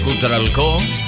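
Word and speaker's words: Cutral Có: I'm sorry, perdón, Aprovecho para que reitero y de esta vez comienzo a pronunciarlo correctamente. Cutralcro. ¿Lo digo bien? Ahí está bien, sí, más o menos, sí. Un abrazo grande a la gente de Neuquén, República Argentina. – Todos tus éxitos se escuchan aquí por la 92.5. – Beso grande por ahí, por como siempre ha ido la tía Gladys, a la gente de Cutralcó. Ahora sí lo dije bien.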